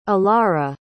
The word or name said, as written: alara